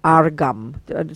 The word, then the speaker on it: Argham: So what the first one is water for, it's like nectar for drinking, and that's called argam. Spell it as it sounds. I have no idea the right spelling. Argam. They often spell it A-R-G-H-A-M.